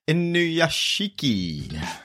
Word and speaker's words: Inuyashiki: But we are watching this week Inuyashiki.